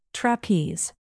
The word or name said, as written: trapeze